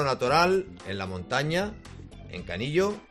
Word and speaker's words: Canillo: Yo pensaba que se pronunciaba Canillo. – No, Canillo. – Canillo, pues Canillo.